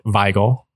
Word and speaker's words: Wigle: In any case, he would have pronounced it Wigle.